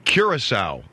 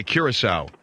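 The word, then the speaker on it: Curaçao: What is C-U-R-A-C-O-U? How do you pronounce that? Curacao. Okay, Curacao.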